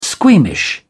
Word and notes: squeamish: British English

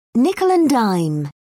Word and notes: nickel-and-dime: British English

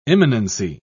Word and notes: immanency: British English

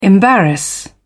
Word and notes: embarrass: British English

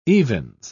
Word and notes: evens: British English

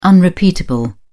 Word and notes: unrepeatable: British English

